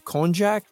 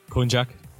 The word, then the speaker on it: konjac: It's made from an herb, konjac. Did I pronounce that right? – Yep, konjac, yeah.